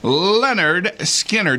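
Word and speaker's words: Lynyrd Skynyrd: A pair of tickets to see Leonard Skinner. That's how it's pronounced.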